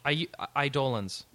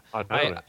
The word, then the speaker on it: Eidolons: Eidolons. – I thought it was Eidolons. So when they start, they actually say Eidolons. I'm like, what? – Oh, I've never seen that, heard that pronunciation.